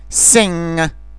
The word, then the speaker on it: sing: Când se termină cuvântul NG, G-ul nu se aude. Este ca și cum ai vrea să-l pronunți și cineva, la un moment dat, te oprește. Sing, ring.